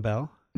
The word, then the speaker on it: Bel: Jerome Bel. – Mm-hmm. – Uh, I said that one right. – Yes, you did.